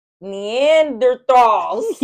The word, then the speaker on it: Neanderthals: When I told her the Dutch come from the Netherlands, she said, no, sweetie, those are Neanderthals. I don't know how to say that. – That is how you say it.